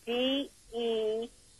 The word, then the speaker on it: DeShazer: Yeah, it's Jacob, and then DESHAZER.